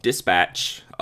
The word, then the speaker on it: DSPTCH: Couple more, Dispatch, which is spelled Dispatch without any vowels.